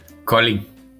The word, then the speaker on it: Kolding: But so, could you just give us the correct pronunciation of Colling? – Colling. – Colling. – Colling. – Okay, yes. – Colling. Okay, silent D.